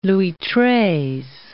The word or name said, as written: Louis Treize